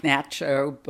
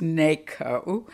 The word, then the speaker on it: NACHO: In 1968, there was a meeting of the North American Conference of Homophile Organization, which looks like Nacho, but you know it is pronounced NACO.